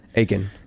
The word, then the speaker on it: Aitken: Aitken.